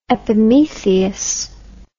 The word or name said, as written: epimetheus